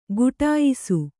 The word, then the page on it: ಗುಟಾಯಿಸು: guṭāyisu